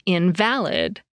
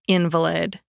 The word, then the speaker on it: invalid: According to Merriam-Webster, the word means being without foundation in fact or truth, when you stress the second syllable, as in invalid. But it means one that's sickly or disabled when you stress the first syllable, as in invalid.